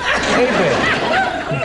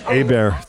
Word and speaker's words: Hebert: You know, it's Herbert, H-E-R-B-E-R-H-E-B-E-R-T. Nothing to pronounce. Try and say it. Hebert. Okay, take a shot. – Hebert.